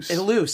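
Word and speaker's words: Ilous: And we only have about another minute, but I wanted to talk very quickly about how Terry Elu, I hope I'm saying his name right. – Elus. – See, yeah. I was like, wait, no, I remember, because I talked to him, and it's been just long enough that I forgot how to say his name, Elus.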